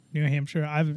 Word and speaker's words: New Hampshire: New Hampshire. It's always been Hampshire. – It's always been New Hampshire, but it sounds like it's the Shire at the end of it.